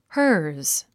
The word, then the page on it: hers: hə’ː r z; ハァズ